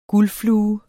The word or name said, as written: guldflue